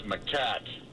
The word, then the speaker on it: macaque: Hey, you're pronouncing it wrong. It's macaque.